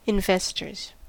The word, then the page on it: investors: American English